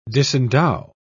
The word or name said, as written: disendow